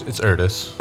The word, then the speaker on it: Ertis: Next up is going to be Ertis. Right? Stress on the first syllable? – It's Ertis.